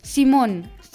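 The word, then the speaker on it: Simón: Simon, hast du gewusst, dass dein Name auf Spanisch anders als auf Deutsch ausgesprochen wird? An Spanien würde es Simon sagen.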